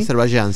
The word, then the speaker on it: Azerbaiyán: Azerbaiyán, sí.